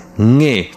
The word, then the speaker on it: Nge: Also zum Beispiel, wenn man mit Stäbchen sich äh, Essen greift, dann heißt es Nge Zai. Also Zai ist das Essen und Nge ist das Greifen, also der NG-Laut am Anfang.